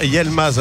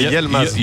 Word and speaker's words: Yilmaz: Est-ce qu'on ne doit pas dire Yilmaz alors, puisque le I se prononce E. Donc exact. – On a Y-I, donc c'est Yilmaz. – Yilmaz. – Yilmaz, pas Yel.